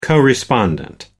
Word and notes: co-respondent: American English

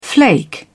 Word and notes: flake: British English

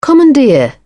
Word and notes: commandeer: British English

